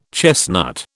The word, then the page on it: chestnut: British English